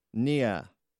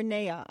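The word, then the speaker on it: Nahas: Nia. Really? Is that how you pronounce that? It's N-A-H-A-S. – Well, Nia or Nea. I'm not sure from she spelled it phonetically, but okay. It's probably Nea.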